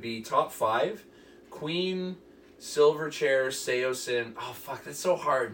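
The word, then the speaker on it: Saosin: Yo, I hate to ruin everyone's night, but this band's name is pronounced Seosin, and I have proof.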